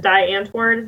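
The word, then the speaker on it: Die Antwoord: Di Antwoord? Is that how you say it? – Yes, ma'am.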